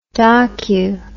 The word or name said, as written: docu